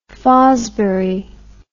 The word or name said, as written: Fosbury